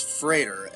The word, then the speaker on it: Freder: My first one was when Fred Frieder, is that how you say the name? The main character's name? – I say Frieder. – Frieder. – Yeah, it's Frieder, and then it's Joe Friedersen.